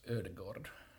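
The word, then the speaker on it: Ødegaard: Mutta toinen yksi, mitä on tällä kaudella odotettukin vähän, niin, ja odotettu jo muutama vuosi ehkä aikaisemminkin, niin Martin Odegaard. – Äh, mun täytyy korjaa nyt, se, se lausutaan norjaksi Ödegaard.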